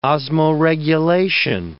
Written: osmoregulation

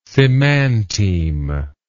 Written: semanteme

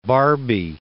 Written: barbie

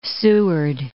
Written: Seward Peninsula

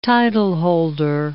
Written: titleholder

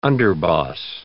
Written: underboss

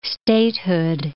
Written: statehood

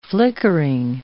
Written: flickering

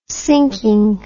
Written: sinking

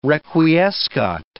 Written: requiescat

